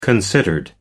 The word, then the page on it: considered: American English